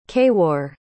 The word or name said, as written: kaywar